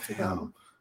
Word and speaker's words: Tagalog: No, that's Philippines. It's Tagalog. – Oh. – It's pronounced Tagalog.